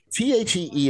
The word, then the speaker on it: Thee: THEE is pronounced the.